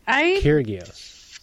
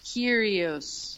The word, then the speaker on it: Kyrgios: Kyrgios. – Kyrgios. – Kyrgios. – I feel like, you know, when you see, like, a gyro on a menu, but you know that you should probably say euro.